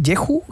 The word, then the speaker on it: Jeju: Jeju. En Jeju, que parece que le encantó.